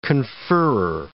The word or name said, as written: conferrer